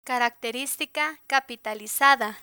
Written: caracteristica capitalizada

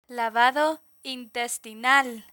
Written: lavado intestinal

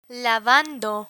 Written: lavando

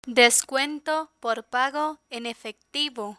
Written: descuento por pago en efectivo

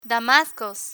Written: damascos

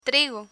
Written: trigo

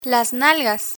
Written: nalgas

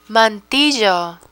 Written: mantillo

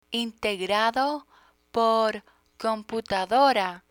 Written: integrado por computadora

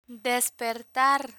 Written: despertar

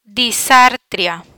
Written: disartria

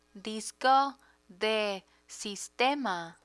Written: disco de sistema

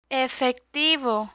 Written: efectivo

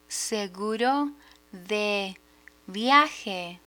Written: seguro de viaje

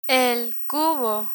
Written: cubo

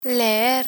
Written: leer

